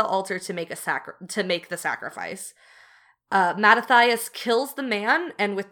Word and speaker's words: Mattathias: So in 166, not 16-6 BCE, Mattathias, I believe is how you say his name. – That sounds right to me.